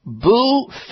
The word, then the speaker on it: Bhu: And lo and behold, there were samples of Boo Fit Bars. Now, Boo is spelled B-H-U.